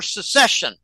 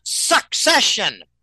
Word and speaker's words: secession: This Texas migrant issue is going to result in a threat for secession. And people need to understand, how do you pronounce this damn word? It is not succession. They're not succeeding anything. They're seceding. It's secession. Secession. Abandoning the union.